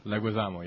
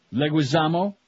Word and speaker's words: Leguizamo: John Leguizamo. I have no idea how you pronounce his name. – Leguizamo, yeah. – Leguizamo,